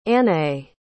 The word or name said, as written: Anaé